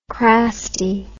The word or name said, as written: crasty